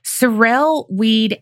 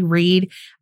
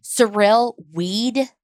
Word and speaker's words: Sorrel-Weed: And I'm going to mispronounce that. I'm going to say Reed. I just know I am because that's what my mouth wants to do with the R's in the first one.